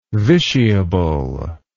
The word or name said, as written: vitiable